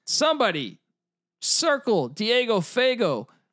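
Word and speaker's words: Fagot: It's F A G O T. Look, I don't know what to say here. – Fago. – Is the T silent? – Silent T. Let's just go with the silent T.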